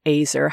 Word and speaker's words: azer: How about if I read that bit of the story and then you talk about the difficulties with translating that word azer? How do you say it in Hebrew? Is it azer? Azer? – Azer. – Azer. Yeah.